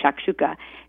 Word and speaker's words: shakshouka: The latter is spelled S-H-A-K-S-H-O-U-K-A, shakshuka.